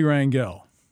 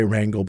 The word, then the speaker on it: Rangel: Charlie Rangel. Am I pronouncing him right? – I would say Rangel, but you're probably right.